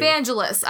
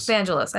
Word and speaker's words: Vangelis: Vangelis. I had one extra syllable. – Vangelis. – I had one extra syllable. I was close.